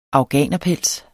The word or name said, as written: afghanerpels